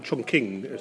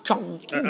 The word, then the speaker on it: Chongqing: I think that's the wrong pronunciation, in China. – Ask Louise. Chongqing. – I didn't. She said I was mispronouncing it. But I think they may have changed the pronunciation, like Beijing and Peking.